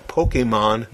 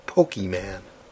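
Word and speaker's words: Pokémon: Just a note, Tim, it's pronounced Pokemon, not Pokeman.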